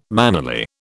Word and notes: mannerly: British English

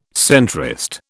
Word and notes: centrist: British English

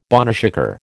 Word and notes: boneshaker: American English

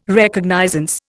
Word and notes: Recognizance: Indian

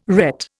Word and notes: ret: Indian

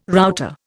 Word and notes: router: Indian

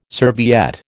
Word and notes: serviette: American English

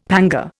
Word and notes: panga: Indian

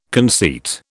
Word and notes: conceit: British English

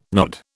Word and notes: nod: British English

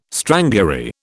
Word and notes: strangury: British English

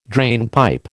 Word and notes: drainpipe: American English

